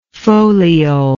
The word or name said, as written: foliole